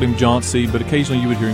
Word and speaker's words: John C.: On one last note, there's a debate on how the name John C. is pronounced, with some pronouncing it John's.